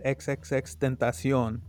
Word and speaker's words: XXXTentacion: And so he said himself, it's XXXTentacion. That's how you say it, you know? – No one ever caught it.